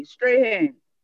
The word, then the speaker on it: Strahan: I hear two different pronunciations for your names, two, Strawn and Strahan. Which one do you use, Strahan? – Long A, Strahan.